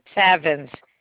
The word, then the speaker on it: Savins: I-L-E-E-N. – E-E-N. Okay. And your last name or initial? – Savins. S-A-V-I-N-S. – S-A-V-I-N-S? – Yes.